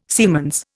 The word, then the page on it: siemens: Indian